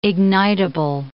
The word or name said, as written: ignitible